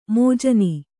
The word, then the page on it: ಮಾಜನ: kannada